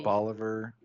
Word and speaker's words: Bolivar: Today is going to be the Bolivar Point Lighthouse, which that is how you pronounce it, because I looked online, and Texas has a multi-page set of pronunciations for cities and locations in the state. – So what, like, it's like Oliver, but with a B.